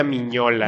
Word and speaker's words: Mignola: ¿De Piñola? – Se pronuncia Mignola.